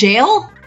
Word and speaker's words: Jael: Oh, wait, do I have to go about Yael and Castiel? – I believe it's pronounced Jael. – Jael? Okay.